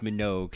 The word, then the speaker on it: Minogue: Kelly and Diana Minnaji, God bless them both. But it's pronounced Minogue.